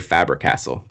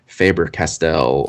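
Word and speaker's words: Faber-Castell: I want to say Faber Castle, but I know it's it's probably Faber Castell or I would you call know, it Faber Castell.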